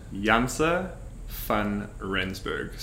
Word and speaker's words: Janse van Rensburg: Jantz van Rijsenberg. So van is like fun, F-U-N.